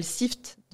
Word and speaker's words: Sift: Sur LinkedIn, que je consulte beaucoup trop, j'ai une, une, extension qui s'appelle Sift de Julien Collet ok.